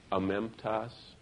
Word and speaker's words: amemptos: Do you know in Greek the word blameless is amemptos, A-M-E-M-P-T-O-S is how they spell it, amemptos, which means without blame attached or without someone who is accused, they're unaccused by God.